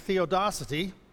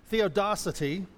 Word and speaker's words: theodicy: Well, the understanding is, here's a new word I learned, theodicy. It's, I pronounced it right. Theodicy, or theodicy, theodicy, theodicy.